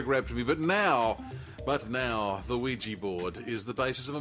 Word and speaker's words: Ouija: Ouija, but it is pronounced Ouija. – Yeah, it is pronounced Ouija.